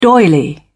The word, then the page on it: doily: British English